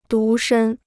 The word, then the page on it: 独身: dú shēn